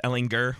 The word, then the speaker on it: Ehlinger: It's Ehlinger with the hard G.